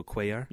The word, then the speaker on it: Cuellar: If we land Cuellar, I think that's how you say it, right, Gustavo Cuellar? – Yeah.